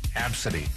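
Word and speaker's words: Abcde: First 5 letters of the alphabet strung together in order. Absidy is how she pronounces it.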